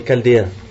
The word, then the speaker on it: Chaldéens: Ça se dit K, mais ça s'écrit CH, d'accord? Les Chaldéens,